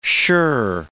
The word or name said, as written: shirr